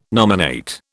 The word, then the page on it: nominate: British English